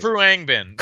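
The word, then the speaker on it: Khruangbin: Like the Kodo, tell me how to say that right. – Kruangbin. Kruangbin. Reasonably yeah.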